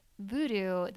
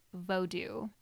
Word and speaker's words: vodou: And it was very education-oriented, and one of the main things that they wanted to tell people is that in Haiti, they don't call it voodoo, they pronounce it vodou.